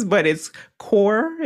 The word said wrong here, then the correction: Korg: Is it Core? – Korg. Korg.